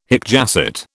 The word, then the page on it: hic jacet: British English